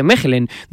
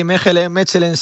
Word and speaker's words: Mechelen: Voy a saludar a Nacho Miras, que es futbolista profesional, guardameta en el Mechelen, creo que se dice Mechelen, de la primera división belga. Hola Nacho, ¿cómo estás? Muy buenas noches. – Hola, ¿qué tal? Buenas noches. – ¿Lo he dicho bien? – Sí, Mechelen, sí. Un poco, aquí se dice más Mechelen, pero hay gente también que lo dice Mechelen. Yo creo que depende un poco.